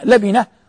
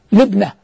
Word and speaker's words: لبنة: وكلمة لبنة تنطق بفتح اللام وكسر الباء لبنة, وتنطق بكسر اللام وسكون الباء لبنة, وكلا الحالين صحيح, كلا قول اللفظين صحيح.